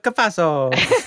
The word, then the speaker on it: Cafasso: Say it right. Joseph Cafasso.